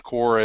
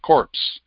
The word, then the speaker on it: corps: Esprit is spirit, de corps, the same corps as you get in Marine Corps, C-O-R-P-S. That's why you pronounce it corps instead of corpse, because it's not an English word, it's a French word.